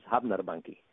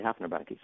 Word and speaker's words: Hafnarbanki: So Hafnarbanki, for instance, they say is Hafnarbanki. You know, so when the Icelandic characters say it, that's how they say it. When the American characters say it, they say Hafnarbanki.